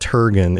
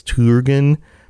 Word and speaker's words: Turgon: Like, it's Turgon, isn't it? It's not Turgon. It's Turgon. Because the U is like the U in Turin.